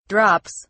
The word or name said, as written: drops